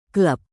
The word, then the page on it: klub: Indonesian